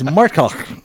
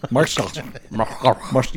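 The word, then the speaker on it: Marxuach: No, it's Marxach. Marxach. You got to get that phlegm in there.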